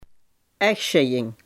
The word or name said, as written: echtscheiding